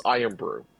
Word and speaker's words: IRN-BRU: It is spelled IRN-BRU. – Okay. – But I think it's pronounced Iron Brew.